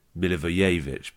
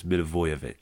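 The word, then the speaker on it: Milivojević: I wasn't sure if it was Milivojevic, but it's Milivojevic.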